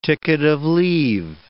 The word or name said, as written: ticket-of-leave